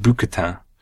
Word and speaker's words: bouquetin: And they've sent us a postcard with 2 goats on the front, alpine mountain goats, which are called Bouquetin. – Spectacular pronunciation.